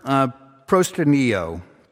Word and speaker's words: proskuneo: It's the word proskuneo. Proskuneo. It's spelled P-R-O-S-K. U-N-E-O, proskuneo, P-R-O-S-K-U-N-E-O.